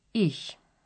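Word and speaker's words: -ig: Several adjectives have the suffix ig, which is pronounced ich.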